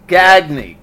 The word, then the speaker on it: Gagne: And then your last name is G-A-G-N-E, Gagne, correct? – Correct. – And how often do people get... What give me the pronunciation again.